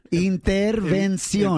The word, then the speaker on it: intervención: Yeah, it's the N. Just get the N, Prima Vani. Intervención.